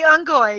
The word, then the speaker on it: Ungoy: I was like, well, am I pronouncing the Sangheili correct? He says, yes, you are. I'm like, okay, so what about the Ungoy? He goes, yes, you're pronouncing those correct too.